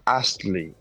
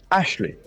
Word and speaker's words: Astley: My surname is Astley, A-S-T-L-E-Y. And everyone, most people pronounce it Ashley. And it drives me doolally, it's pronounced.